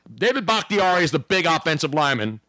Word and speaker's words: Bakhtiari: Bakhtiari. – I even phonetically spelled it. Back, B-A-K-K-T-E-E-R-E-E. Bakhtiari. Should have put the hard R yes.